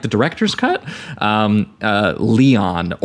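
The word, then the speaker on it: Léon: I think it's the French name. It's Leon. Okay. – Leon.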